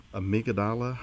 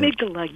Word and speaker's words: amygdala: You know, a few days ago, I was participating in a conflict management seminar, and one of my co-presenters, she talked about this particular amygdala hijack, if I pronounced it correctly. – And amygdala, yes. – Amygdala, thank you.